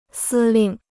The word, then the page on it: 司令: sī lìng